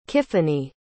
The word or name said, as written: kiffany